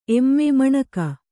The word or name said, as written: ಏಮ್